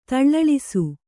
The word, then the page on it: ತಳ್ಳಳಿಸು: taḷḷaḷisu; kannada